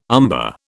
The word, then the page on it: umber: British English